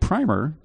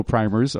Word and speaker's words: primer: Is it primer or primer? I've always mispronounced it. It depends what part of the country you're from. – Oh, okay. – You're from the east, as I recall.